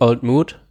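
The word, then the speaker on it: Old Mout: Stimmt, auf der Webseite steht Old Mood, in Klammern, rhymes with fruit.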